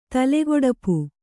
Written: ತಲೆಗೊಡಪು

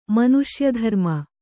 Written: ಮನುಷ್ಯಧರ್ಮ

